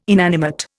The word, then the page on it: inanimate: Indian